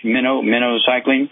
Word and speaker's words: minocycline: It's pronounced like the bait fish, minocycline.